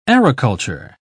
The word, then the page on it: aeroculture: British English